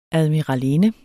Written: admiralinde

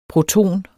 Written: PROTONER

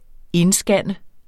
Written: indscanne

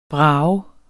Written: brages

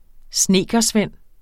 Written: snedkersvend

